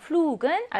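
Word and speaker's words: Flug: 그럼 flug은 알파벳 G가 어디에 있어요? 단어 음절 끝에 있으니까 본래의 발음이 아니라 크 발음을 내는 경우이네요.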